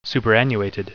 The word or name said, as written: superannuated